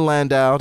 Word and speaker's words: Landau: But this play written by McCraney and Tina Landau, that's how you say it.